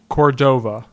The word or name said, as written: Cordova